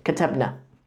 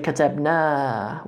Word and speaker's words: katabna: is because when we're saying in the first person, we say katabna, we have a long a on the end. Katabna. Nahnu katabna, we wrote.